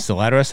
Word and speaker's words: Zalatoris: I'm going with Zalatoris. I don't know why it sticks in my head, Zalatoris, but it's Zalatoris. But I reckon it's an overuse of, what are they called, syllables? Zalatoris. So you can go with Zalatoris.